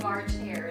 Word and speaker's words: March Hare: She was headed to a tea party with the Mad Hatter at the March Heart. – How do you say that? The March Hare is going.